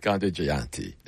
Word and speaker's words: Gandhi Jayanti: I'll say it right. Gandhi Jayanti. There we go.